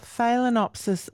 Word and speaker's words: Phalaenopsis: Are Phalaenopsis orchids okay in the bathroom? Did I pronounce that correctly? – You certainly did.